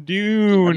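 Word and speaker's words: Dunne: Dune. – I believe that's how you pronounce it now.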